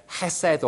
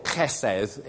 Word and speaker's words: chesed: Hesed or chesed is the way to pronounce it.